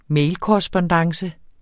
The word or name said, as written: mailkorrespondance